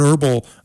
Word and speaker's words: herbal: And herbal, that has a silent H too.